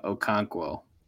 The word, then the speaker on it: Okonkwo: Okinkool. – I think it's also known as... – I do know how to pronounce that name. It's Chigozim Okonkwo.